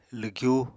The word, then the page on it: lwgyu: apatani